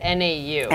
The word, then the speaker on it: Nau: Is that how you pronounce it? NAU. – NAU.